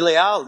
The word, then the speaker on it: Leal: I guess it was always in New York Leal, but then in Miami it came to be Leal now, like it is in Spanish. But that was a Miami thing.